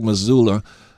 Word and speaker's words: Mazzulla: I think guys like Mazzulla...